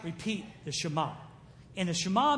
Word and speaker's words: Shema: It's spelled S-H-E-M-A, which is Shema. Okay, now I'm going to say that sometime. Just be ready. But it's pronounced Shema. Say Shema. Shema.